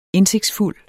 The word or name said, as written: indsigtsfuld